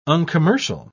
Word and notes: uncommercial: British English

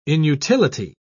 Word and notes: inutility: British English

